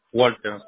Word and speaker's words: Walter: Ahora, su primer nombre se escribe W-A-L-T de Tomás, E de Eduardo, R de Roberto. ¿Walter? – Sí, Walter, sí, correcto.